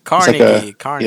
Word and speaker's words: Carnegie: I'm surprised because I only have two more, but I'm surprised you didn't mention this one, Carnegie. I hope, is that how you say it, Carnegie? – Carnegie. Yeah, like a hard G.